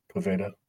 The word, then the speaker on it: Poveda: I thought his name was... Because why not? That's how it's spelled. But I'm since learning that it's Ian Poveda.